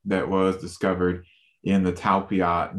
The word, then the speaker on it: Talpiot: And you talk about the relationship between Mary Magdalene, and you mentioned the, now correct me if I'm wrong how to pronounce this, the Talpiot tomb? – Talpiot tomb, yes. Okay, I knew I was saying that wrong.